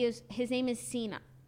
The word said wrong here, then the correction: Senan: Well, I don't know how to pronounce it. Sinan, S-E-N-A-N. Sinan.